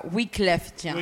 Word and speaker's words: Wyclef Jean: Nada, y poco después se le unió eh, eh, Wyclef Jean, que realmente se pronuncia Wyclef Jean.